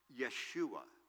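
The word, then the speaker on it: Yeshua (ישוע): And Joshua in Hebrew is pronounced Yeshua.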